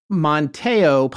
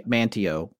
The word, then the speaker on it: Manteo: Monteo, possibly, but I believe North Carolinians say Manteo because right.